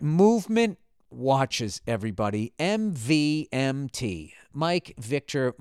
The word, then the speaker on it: MVMT: Uh, with fair prices, unexpected colors, and clean original designs, MVMT, pronounced movement, grew into one of the fastest growing watch brands, shipping to over 160 countries across the globe, globe, globe, globe.